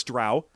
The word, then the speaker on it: drow: So I know it's drow because it says it rhymes with cow. Huh. It doesn't rhyme with co.